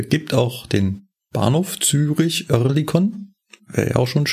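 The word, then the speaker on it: Oerlikon: Ich habe mir den Begriff Örlikon, mal gucken, ob ich das besser aussprechen kann, Örlikon genommen.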